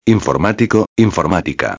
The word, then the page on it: informatico-a: espanhol